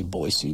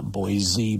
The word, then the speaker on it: Boise: I know they say Boise, not Boise, but I'm in Montana. That's how we pronounce it.